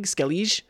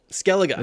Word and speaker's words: Skellige: Skellige? – It's Skellig. – Skellig? – No, Skelliga. – Skelliga. – Skellig. – Skellig. Skellig. Okay.